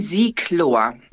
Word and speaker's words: Sieglohr: Sieglohr oder Siegelohr? – Nein, Sieglohr. – Okay. – Ohne E. – Ja. Sieg wie der Fluss und Lohr wie, was weiß ich, das Ohr mit dem L davor.